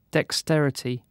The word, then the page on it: dexterity: British English